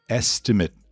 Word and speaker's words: estimate: Mas é estimate a pronúncia. Porque o verbo to estimate, que é estimar, é orçar, é calcular. Aí sim, estimate. Estimate. Estimate é o orçamento.